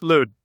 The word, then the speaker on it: Lüt: Did I pronounce Lüt correctly? – That's right. – Because it's got an umlaut above the U. – Right. Just like L-O-O-T would be pronounced. Lüt.